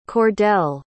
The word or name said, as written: kordell